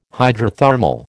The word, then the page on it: hydrothermal: American English